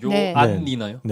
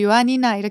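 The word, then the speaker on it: Ioannina: 요안니나요? – 네. 네네. – 요안 N이 두 개라서 제가 그렇게 하는데 그들은 그냥 요아니나 이렇게 발음을 네네. 할 거예요.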